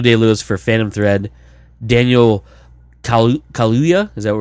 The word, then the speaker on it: Kaluuya: Kaluuya, is that how it is? – I would say so.